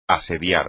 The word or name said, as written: asediar